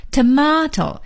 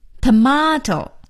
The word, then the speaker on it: tomato: T-O-M-A-T-O, tomato, 西红柿 tomato, 美音的发音呢是念成 tomato, T-O-M-A-T-O, tomato, 所以 tomato 和 tomato 都是对的。